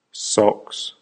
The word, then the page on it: socks: American English